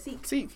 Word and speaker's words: Sikh: S-I-K-H. – But how do you pronounce it? – Seek, yeah, like that. – S-W-E-K. Thought it was sick.